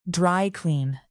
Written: dry-clean

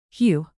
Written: hew

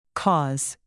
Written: cause

